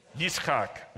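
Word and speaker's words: Yitzchak (יצחק): How do you say that in Hebrew? Yitzchak.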